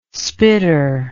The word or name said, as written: spitter